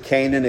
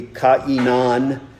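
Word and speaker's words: Cainan: He lived 90 years and begat, I pronounce it Canaan. It could be Ka'inan. It's not Canaan like the land of Canaan, but that was the name of this man.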